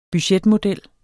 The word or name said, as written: budgetmodel